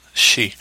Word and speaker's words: Shi: And he says they're called the Shi, I believe is how you pronounce it, S-H-I. She.